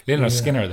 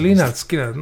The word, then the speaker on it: Lynyrd Skynyrd: 1985 muuses see mängis passi Lünnürd Skünnürd tii passimees. Ja kui üks määls on selle bändi nime õigesti praegult. – Enam vähem. Ma ei ole ka hääldamise spetsialist. Lynyrd Skynyrd öeldakse. – Lynyrd Skynyrd, no võt näed, enam vähem.